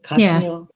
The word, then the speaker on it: cochineal: cochineal that how you say it? – Cochineal? – Yeah. Cochineal. Yeah.